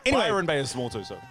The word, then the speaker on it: Byron Bay: I would say Cairns, dope, but Cairns, Darwin, Townsville, Rockhampton, Sunshine Coast, Bryan Bay, Gold Coast, Gosford, Coffs Harbour. – Stop. You can't just call it Bryan Bay. – Why? – Because it's not Byron Bay.